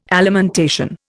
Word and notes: alimentation: Indian

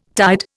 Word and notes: tied: Indian

